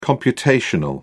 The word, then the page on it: computational: British English